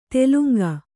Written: ತೇಲುಂಗ

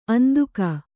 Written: ಅಂದಿಗ

